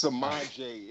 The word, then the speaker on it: Samaje: It's Samaje. It's Jane spelled backwards. – Is it really? – It's a silent E.